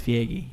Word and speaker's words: Feige: How you pronounce his name? – I believe it's Feige, yeah.